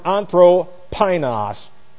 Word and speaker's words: anthropinos: Of the Greek word anthropinos, which looks like this. A-N-T-H... R-O, and then we have P-I-N-O-S. This is A-N-T-H-R-O, long O, P-I-N-O-S. Anthropinos.